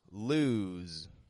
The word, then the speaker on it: lose: So when we pronounce the word lose, we're making sure that the S sounds like a Z. We're giving it voice.